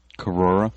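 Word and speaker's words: Korora: Because there's an extra syllable in there that Cora is just missing. – But if you move your head kind of fast, it sort of blends in together because it's just O-R-O-R. Corora.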